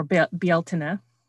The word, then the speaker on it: Beltane: Beltane is Celtic, and for you listening, or Beltane, as it is apparently correctly pronounced.